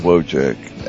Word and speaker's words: Wojcik: Yeah, it's at my name is Wojcik, and it's spelled W-O-J-C-I-K.